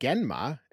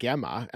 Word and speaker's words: Genma: You said Genma and I said Gemma, and I know it's spelt Genma, but all that I hear them saying is Gemma.